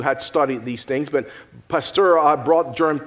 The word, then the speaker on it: Pasteur: Some of you may remember in school hearing about Louis Pasteur. I don't know if that's how you say it. Any French people here? Pasteur. Is that? Okay. There you go.